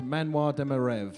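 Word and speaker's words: Manoir de mes rêves: In French, it is pronounced Manoir de Merev.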